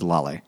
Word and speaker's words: Lale: I looked up, how do you pronounce this? And it was a video of her, the woman who wrote it, talking about her interview of the guy. So it was like, it was Lale.